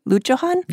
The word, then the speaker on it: Lutjohann: Am I saying that right, by the way? Luchohan? – Yeah. – Cool. – It's German.